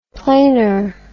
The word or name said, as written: plainer